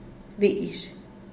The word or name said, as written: dizh